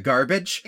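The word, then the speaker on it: Garbitsch: I mean, to be fair, it's spelled G-A-R-B-I-T-S-C-H, I think. But it sounds like garbage.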